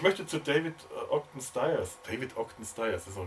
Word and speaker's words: Stiers: Ich habe mal ein Interview mit Jamie Farr, dem äh, Schauspieler, der Max Klinger gespielt hat, gehört und der hat das so ausgesprochen. Danach habe ich das geübt. David Ogden Stiers.